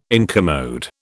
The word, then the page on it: incommode: British English